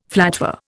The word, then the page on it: flatware: Indian